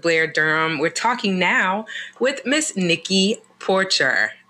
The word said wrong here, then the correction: Porcher: You didn't butcher it, but it's pronounced Porsche. It's okay. – Porsche.